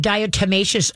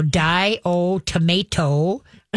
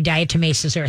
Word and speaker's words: diatomaceous: Okay, and you know how long, many years it took me to say that correctly? And how what I would think of is dye, and then earth, and then dye-o, then tomato, and then earth. So die-o-tomato earth before I finally could put it together going diatomaceous earth.